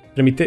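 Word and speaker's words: trâmite: Como, como tem muita coisa, envolve muita coisa de tramite legal ali, né? Tramite, eu não sei como é que se pronuncia essa palavra, desculpa. – Tramite. Tramite, obrigado. É, então acertei. É, então eu acho que eles nem podem falar certas coisas, né?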